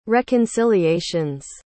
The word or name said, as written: reconciliation's